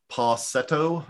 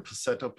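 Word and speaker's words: PASETO: There was cookies, macaroons, Passetto, I think is how it's pronounced, or Passetto, P-A-S-E-T-O. I think so, Passetto, yeah.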